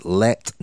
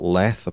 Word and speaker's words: Leth: It's Leth, not Leth, apparently.